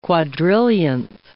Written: quadrillionth

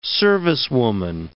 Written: servicewoman

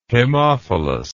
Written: hemophilus

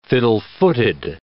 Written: fiddle-footed

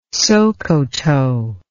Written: Sokoto

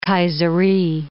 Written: Kayseri

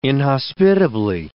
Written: inhospitably

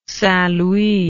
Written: saintlouis

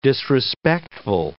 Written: disrespectful

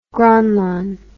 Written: Gronland

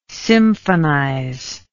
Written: symphonize

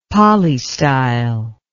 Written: polystyle

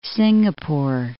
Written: Singapore

